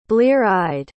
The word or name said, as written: blear-eyed